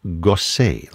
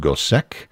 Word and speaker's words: Gossec: Last name is spelled G-O-S-S-E-C. You might think that would be pronounced Gosek, but we know that he himself pronounced it that way because he would spell his name differently, sometimes with an E-Z on the end, an E-T, whatever it is.